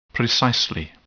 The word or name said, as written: precisely